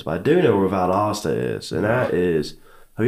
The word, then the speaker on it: Valdosta: So, like, nobody knows how to even pronounce Valdosta, by the way.